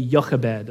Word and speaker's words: Jochebed: Later on, I think in Exodus chapter 7, we'll learn that his father's name is Amram or Amram, and his mother's name, it's spelled with a J, but I say Jochebed, okay?